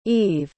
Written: yves

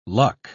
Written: luck

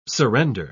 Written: surrender

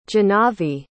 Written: jahnavi

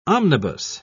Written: omnibus

